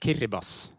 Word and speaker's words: Kiribati: Jeg hadde sagt Kiribati om jeg fikk valget, men det ikke sånn det skal sies. – Nei, det riktig, og det uttales rett og slett Kiribas.